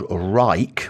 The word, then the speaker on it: Wrike: And the two that came up in conversation was one called Rike, which is spelled Wrike,